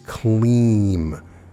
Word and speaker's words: Klim: The third Bija mantra of significance is Kleem, pronounced K-L-E-E-M, spelled K-L-I-M. When chanting, you drag out the middle part like we did with Shreem. Kleem.